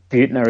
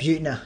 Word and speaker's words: Buettner: But pretty much the brief of it is that Dan Buettler, how do you pronounce his name? – I think it's Buettner, isn't it? – Buettner. – Yeah, Buettner, that's it, yep.